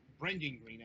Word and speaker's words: Brendan Green: And that's actually more relevant than you think, because I'm not sure if it's a controversy, but Brandon Green or Brendan Green, actually, I believe that's how that's pronounced.